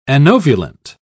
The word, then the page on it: anovulant: British English